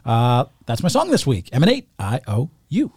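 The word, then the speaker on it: Eman8: This week for me, I believe his name is Emanate. I believe is the way that you pronounce it. It's E-M-A-N-8, but I stumbled across his music. He's got a song called I-O-U.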